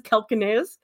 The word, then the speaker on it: calcaneus: You guys, do you know how I know how to pronounce that? It's because there's a Quantum Leap episode where Dr. Sam Beckett lists off the names of all these bones and he says calcaneus. That's the only reason I know how to pronounce that word.